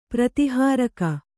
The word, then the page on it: ಪ್ರತಿಹಾರಕ: prati hāraka